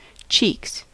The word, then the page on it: cheeks: American English